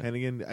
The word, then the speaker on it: Hennigan: Hennigan? – Hennigan, yeah. – Hennigan.